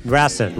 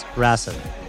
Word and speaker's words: Racine: Racine. – Was it? – Racine. – Nope. We're going to say it right. We're going to respect the people of this town.